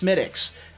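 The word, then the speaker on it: Smithwick's: I don't understand how this can be pronounced Smittix. There's an H and a W in there. Smittix apostrophe S. – Damn good beer. – It is good. It's excellent, but it's pronounced all fucking wrong.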